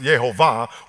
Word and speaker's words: Jehovah: Now, this is where we get the word, what we call Jehovah, which is actually pronounced Yehovah, which comes from the word Yahweh, which really no one knows how to pronounce. So just simplify Yehovah.